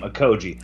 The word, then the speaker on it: Okogie: I like your take on Okogie, and I like how you say his name, Okogie.